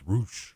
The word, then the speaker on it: Rush: The Rush versus Sean John John fight was incredible. – Roosh. – Roosh. – Spelled R-U-S-H, but it's pronounced Roosh.